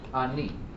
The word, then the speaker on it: ani: How do you say it in Hebrew? You know the word ani? You've heard this word before? Ani? The Hebrew word for I is ani.